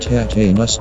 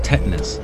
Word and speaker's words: tetanus: I would rather have tetanus than rabies. – It's pronounced tetanus, not tetanus.